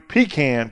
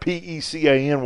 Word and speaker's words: pecan: The Oxbow Bakery pecan pie, and I'm only saying that because I'm in South Carolina, which is how you pronounce that P E C A N word, not pecan.